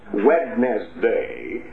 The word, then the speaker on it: Wednesday: She just knew that I was supposed to spell it Wednesday instead of Wednesday, the way we pronounce it with a silent D.